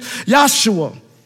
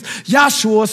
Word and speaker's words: Yahshua: And here it says Jesus, but in the Hebrew, his name is Yahshua. The same name is Joshua, but they didn't pronounce J's. It was Yahshua.